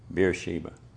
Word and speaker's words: Beersheba: He's in Bathsheba. Did I say that right? Bathsheba is a woman. Beersheba. Right.